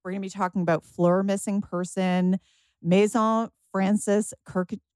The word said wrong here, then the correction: Kurkdjian: Oh, Jill, how do you say this one? Help me out. – Maison Francis Kirkdjian, Baccarat Rouge 540.